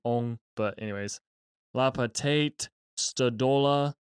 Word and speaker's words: Weng: First author is Ong, is how you say it in Chinese, but it's W-E-N-G in English. You probably say Wang or something.